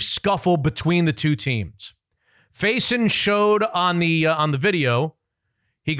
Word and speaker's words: Faison: His name is Jeremy Faison. I think that's the way you pronounce it. – Okay. – F-A-I-S-O-N.